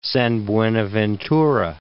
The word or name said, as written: San Buenaventura